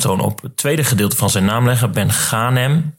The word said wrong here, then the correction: Ben Ghanem: Zie je, ik ging alweer fout. Het is lastig. Samir Ben Ghanem.